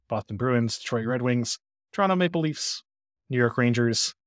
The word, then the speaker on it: Toronto: Toronto. Sorry. You don't pronounce the second T, apparently.